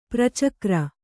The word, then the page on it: ಪರಚಕ್ರ: Kannada